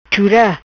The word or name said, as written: Chura